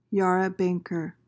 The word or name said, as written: yarra-banker